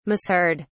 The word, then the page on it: misheard: British English